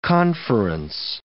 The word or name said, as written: conference